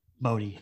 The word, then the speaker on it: Bodhi: They just killed Brody to kill Brody. – Brody? – Brody? – Bodhi. – Bodhi. Well, I've been saying this wrong the entire podcast.